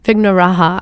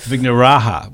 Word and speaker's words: Vignarajah: The last name is Vignarajah, Vignarajah. – Vignaraha, I believe. – Vignaraha, where the J is a soft J.